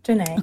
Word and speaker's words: J'Nai: I wanted to ask you, you're gonna think I'm nuts, but your name, you pronounce it Janae, right? – Yeah. Mm-hmm. Janae.